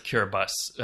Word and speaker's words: Kiribati: uh, I think it's actually pronounced Kiribati. Um, that's my mistake. I said Kiribati.